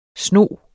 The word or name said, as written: sno